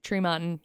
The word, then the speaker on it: Tremonton: Tremonton. – Tremonton, yep.